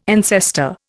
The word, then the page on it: ancestor: Indian